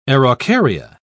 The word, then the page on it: araucaria: British English